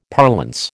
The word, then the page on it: parlance: American English